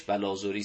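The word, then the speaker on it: بلاذری: بله، بلازوری، تلفظ صحیحش بلازوری است، اشتباه تلفظ نکنید.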